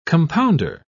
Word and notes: compounder: British English

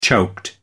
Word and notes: choked: American English

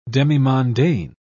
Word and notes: demimondaine: British English

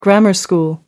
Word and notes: grammar school: American English

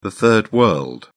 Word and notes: third world: British English

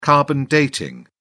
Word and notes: carbon dating: British English

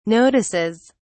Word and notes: notices: British English

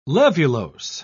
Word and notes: levulose: British English

